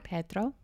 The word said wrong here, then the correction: Pietro: Did I say it wrong? – There's just like, you almost say Pedro. There's a like Pietro.